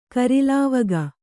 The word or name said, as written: ಕೊರಲ್ವಾಗು